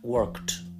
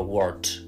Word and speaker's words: worked: yeah th growth the growth is very big yeah and last one one pronunciation to remember trabajar work the past worked ojo que al decir worked si no hay mucha práctica es fácil como olvidar la K, porque no es del todo fácil pronunciar una K y una T seguidas, que suenan así como un kt, kt, kt, pero existe y hay que marcarla, no vale decir solo word, se pierde la K, entonces no, no se entiende la palabra.